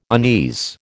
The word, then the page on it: unease: American English